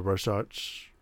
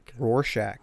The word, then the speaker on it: Rorschach: And the plot seemed to devise somewhere around the Rorschach. – Wow. I always say that wrong. – You can't say it. – Rorschach.